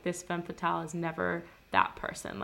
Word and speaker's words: femme fatale: Anyways, I don't know what I've been saying so far, but I just looked it up and it's femme fatale.